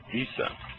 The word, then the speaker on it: Isa: There is another name which is used extensively as his name in the East, that is the same name Isa, E pronounced as in Emmanuel, and S as in the Son, or Isa the Messiah.